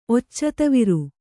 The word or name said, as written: ಒಚ್ಚತವಿರು